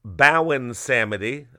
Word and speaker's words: Baron Samedi: People also call him Baron Samedi or Bowen Samedi, I guess if you're cute in five. – I feel like I remember in high school hearing Haitian dudes pronounce R's more like W's.